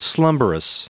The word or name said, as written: slumberous